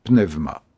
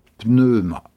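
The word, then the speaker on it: pneuma (πνεῦμα): En grec, la langue dans laquelle nos évangiles ont été écrits, le mot qu'on traduit par esprit, c'est « pneuma ». Je le prononce comme en grec moderne. La prononciation scolaire moins authentique est aussi moins jolie. On a coutume de dire « pneuma ».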